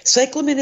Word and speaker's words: cyclamen: It's C-Y-C-L-A-M-E-N. – Mm-hmm. And it is a lovely plant. It's the way you pronounce that is cyclamen.